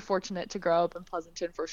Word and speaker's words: Pleasanton: You grew up, or at least laterally, in a town, and I hope I'm pronouncing it right, Pleasanton. Is that correct? – That is correct.